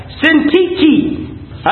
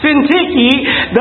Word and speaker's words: Syntyche: Syntyche. I know that's a hard word to pronounce, but that's the way it's pronounced. Syntyche, that they be of the same mind in the Lord.